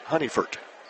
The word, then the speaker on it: Honigford: Spelled H-O-N-I-G-F-O-R-D, pronounced Honeyford.